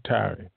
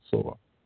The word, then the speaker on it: Tyre: If we read through Isaiah 23, it talks about the city like likened to Soar, Tyre, T-Y-R-E is actually pronounced Soar. Very much sounding like Zoar.